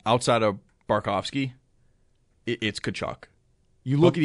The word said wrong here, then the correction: Bobrovsky: even Bobrovsky.